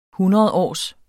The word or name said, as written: hundredårs